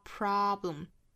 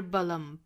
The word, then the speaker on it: problem: 那同样 there 和 isn't 连读、啊、这属于是美音里面呢是辅加元的连读了 There isn't a problem 这个 problem 注意美音呢元音是 a problem 那大家注意这个 problem 这个 b 和 l 的发音中间不要顿开了不是 problem 不是 balum 应该这个 b 和 l 的发音呢是紧紧的连在一起中间不能够断开应该是一个 bl 不是 bl 而是 bl